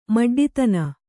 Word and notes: ಮಡ್ಡಿತನ: Kannada

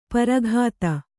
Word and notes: ಪ್ರಘಾತ: praghāta

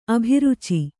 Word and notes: ಅಭಿರುಚಿ: abhiruci

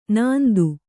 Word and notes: ಣಾಂತ: ṇānta

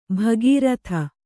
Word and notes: ಭಾಗೀರಥಿ: bhāgirathi

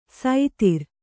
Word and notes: ಸೈತಿರ್: Kannada

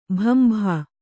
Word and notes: ಭಂಭಾ: kannada

